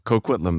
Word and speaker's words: Coquitlam: You're in Coquitlam? – Yeah. Coquitlam, that's right. You got it.